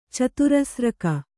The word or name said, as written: ಚತುರಸ್ರಕ